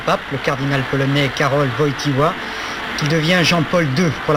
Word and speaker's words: Wojtyła: Wojtyła, c'est la prononciation juste. – Nous, on disait plutôt Wojtyła. – Mais la vraie prononciation, c'est Karol Wojtyła.